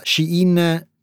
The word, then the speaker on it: Shein: e sappiamo esattamente come fa Shein, che si pronuncia così perché nonostante l'azienda sia cinese il suo nome è una crasi di due parole inglesi che vogliono dire lei dentro.